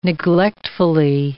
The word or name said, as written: neglectfully